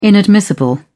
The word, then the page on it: inadmissible: British English